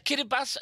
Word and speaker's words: Kiribati: In Kiribati, she moved to Dunedin to study at the University of Otago in 2015.